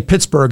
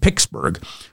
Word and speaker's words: Pittsburgh: McKenzie said he, although he didn't say Pittsburgh, he said Pittsburgh. Please tell McKenzie that Pittsburgh is spelled with T's and not K's.